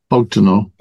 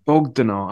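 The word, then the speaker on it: Bogdanor: The essay from um, Professor Vernon B- Bogdanov. – Bogdanov. I always get that pronunciation wrong.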